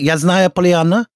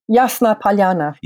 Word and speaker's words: Yasnaya Polyana: Yasnaya Polyana? Yasna Polyana.